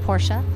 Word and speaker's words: Porsche: Should we be saying Porsche? – You could say Porsche, but it is said Porsche.